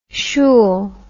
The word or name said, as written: shool